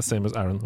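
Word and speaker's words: Samus Aran: Her, her, er Samus Aaron, Vent, da.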